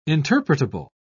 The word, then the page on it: interpretable: British English